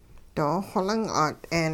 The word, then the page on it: doo-xoling 'at'een: hupa